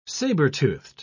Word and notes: saber-toothed: British English

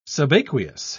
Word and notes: subaqueous: British English

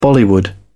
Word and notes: bollywood: British English

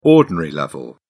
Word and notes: ordinary level: British English

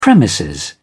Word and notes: premises: British English

